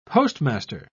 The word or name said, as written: postmaster